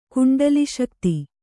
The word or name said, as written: ಕುಂಡಲಿಶಕ್ತಿ